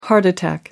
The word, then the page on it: heart attack: American English